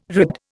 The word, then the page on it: ribbed: Indian